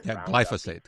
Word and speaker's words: glyphosate: Glyphosate. Glyphosate, that's pronounced.